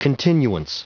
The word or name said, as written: continuance